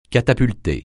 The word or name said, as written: catapulter